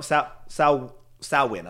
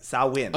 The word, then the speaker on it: Samhain: Do you know Samhain? I'm sorry, Samhain.